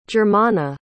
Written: germana

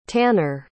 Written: tannar

